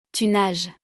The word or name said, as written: tu nages